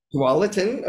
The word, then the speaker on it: Tualatin: Is it Tulalip? Is that how you say it? – Tulalip. – Tualatin, okay, all right.